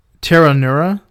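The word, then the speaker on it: Pteronura: The genus is Pteranura. There's a silent P.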